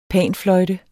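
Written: panfløjte

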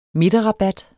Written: midterrabat

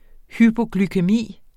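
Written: hypoglykæmi

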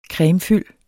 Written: cremefyld

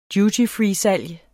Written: dutyfreesalg

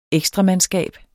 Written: ekstramandskab

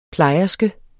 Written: plejerske

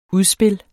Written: UDSPILS

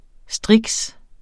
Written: striks

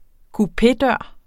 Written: kupédør

